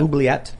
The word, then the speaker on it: Oubliette: Oubliette.